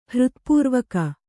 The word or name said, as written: ಹೃತ್ಪೂರ್ವಕ